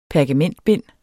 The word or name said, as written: pergamentbind